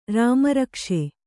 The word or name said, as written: ರಾಮರಕ್ಷೆ